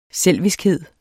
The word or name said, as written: selviskhed